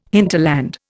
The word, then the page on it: hinterland: Indian